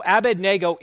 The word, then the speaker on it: Abednego: Now, Abednego is actually a compound of two Aramaic words. It means the servant of Nebo. Abednego is the actual correct way to say that, but I have good intel that some of you simply could not get past that pronunciation.